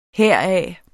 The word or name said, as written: heraf